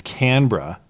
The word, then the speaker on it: Canberra: That's the thing, is this photo is from Canberra, which I looked up how to pronounce the Australian capital before the show, because I know I can't say Melbourne. I got to say Melbourne. And it's Canberra, which is the city that they had this meeting.